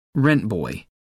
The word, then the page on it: rent boy: American English